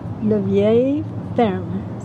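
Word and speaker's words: La Vieille Ferme: Le Vieil Ferme.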